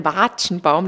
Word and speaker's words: Watschenbaum: Also erstens mal ist es kein Watschenbaum, sondern ein Watschenbaum. Das A wird ganz lange ausgesprochen.